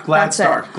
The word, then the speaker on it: Gladstar: Now, fire cider is an old tonic that was actually created by Rosemary Gladshire. Did I say her name right? Sorry, Rosemary, if I said your last name wrong. – Gladstar.